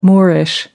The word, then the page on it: moorish: American English